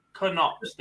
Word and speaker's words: Knops: Again, Knops. The K is not silent.